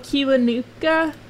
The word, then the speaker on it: Kiwanuka: Michael Kiwanuka? – Yeah. – Right? Is that how you say it? – I believe that's how you say it, yeah.